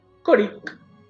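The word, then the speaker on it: Ćorić: ¿Cómo se escribe Borna Choic? – Coric. Ah. las dos veces. Muy forno. Borna y Coric.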